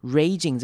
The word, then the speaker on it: raging: Raging 这个字要注意的是长母音 A 的念法， Raging narcissist。 Narcissist 可能是这次比较难念的字，注意到它的两个 I 到念成短母音 I 的声音，开头的 AR 念成 R。